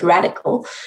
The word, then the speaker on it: RDCL: And so the mnemonic is RDCL, or you can pronounce it radical, so that you answer these four questions in detail.